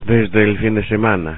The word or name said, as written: desde el fin de semana